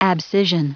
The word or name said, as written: abscission